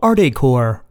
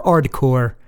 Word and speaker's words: Ardecore: And yes, if you pronounce the band name quickly, it sounds like you are pronouncing the word hardcore with an Italiano accent.